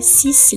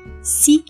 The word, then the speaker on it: six: A gente não vai pronunciar o S finalzinho do cis, não é cis livre, é si livre.